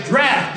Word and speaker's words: draught: and let down their nets for a draught, or drought, however you want to pronounce it. We're going to say draft this morning because that's the way it's supposed to be pronounced, draft.